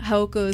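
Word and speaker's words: Jaojoco: But yeah, Haoko is spelled J-A-O-J-O-C-O. I know it doesn't sound like that, but that's what it is.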